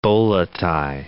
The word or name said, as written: bola tie